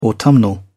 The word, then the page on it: autumnal: British English